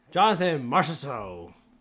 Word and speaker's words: Jonathan Marchessault: Jonathan Marchessault.